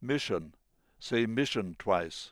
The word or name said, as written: mission